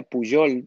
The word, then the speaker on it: Pujol: El tercer día tenemos un invitado especialísimo que se llama Óscar Pujol, o pues, se pronuncia Pujol, porque es del catalán. Esa J, por cierto, es la misma pronunciación que tiene el sánscrito. La J se pronuncia como una Y, como Patanjali, ¿verdad?